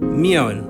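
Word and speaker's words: Mjøen: It's MJO with a slash through it, EN. Mjoen.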